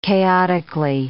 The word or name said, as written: chaotically